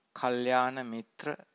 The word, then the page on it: kalyana-mitra: Pali